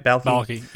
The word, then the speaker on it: Balki: Balki.